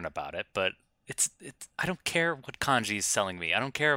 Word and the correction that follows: Kinja: Kanji, it's Kinja.